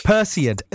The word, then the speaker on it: Perseid: And can I just ask, and one am I saying it right, the Perseid meteor shower? Is that are you going to be yes. – Perseid.